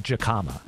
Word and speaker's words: jicama: Make those jicama fries delicious. – Jicama fries. – They're so good. – I believe it's pronounced jicama.